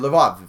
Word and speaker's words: Lvov: or Lvov if you're using um, a, uh, a Russian translation Russian uh, uh pronunciation of that.